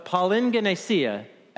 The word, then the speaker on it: palingenesia: So, palingenesia, accent on the last word.